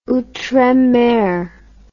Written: outremer

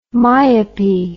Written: myopy

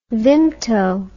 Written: vimto

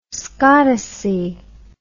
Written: scottice

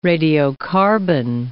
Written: radiocarbon

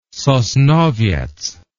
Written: sosnowiec

